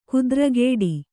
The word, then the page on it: ಖುದ್ರಗೇಡಿ: Kannada